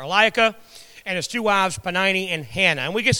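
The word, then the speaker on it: Peninnah: And look, April's going to dispute with me that it's pronounced that way. I, I had it. I looked it up. It's Panini. Uh, anyway, where was I? Panini uh, had children, but Hannah had no children.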